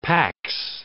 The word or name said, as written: pax